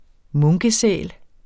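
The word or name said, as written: MUNKESÆL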